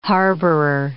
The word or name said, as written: harborer